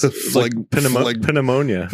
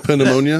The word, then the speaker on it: pneumonia: Pneumonia. – Pneumonia? – Yeah. Why the fuck is there a P in that word? – Mononucleosis.